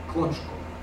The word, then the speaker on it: Kroczko: Quant à mon nom à moi, c'est un nom d'origine lituanienne, écrit à la polonaise. Voilà. Parce que mes ancêtres lituaniens sont venus pour gouverner la Pologne avec le roi Jagellon, qui était le duc de Lituanie, au XIVe siècle. Et ça se prononce Kroczko.